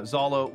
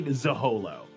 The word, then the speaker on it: Zehollow: Yeah, Zolo, which is spelled Z E O L L O W. – I was saying it's it's like Massachusetts rules, where it was it, it the way it's pronounced looks nothing like it's spelled. Nothing at all. Okay. It's bronze Z E and then hollow Z E H O L O L L O. Oh God. it looks like Zaholo.